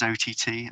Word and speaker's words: Ott: Some people know him as OTT. I think he's pronounced Ott.